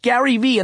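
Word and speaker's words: GaryVee: Gary V with two silent E's.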